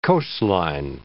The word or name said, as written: coastline